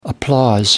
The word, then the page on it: applause: American English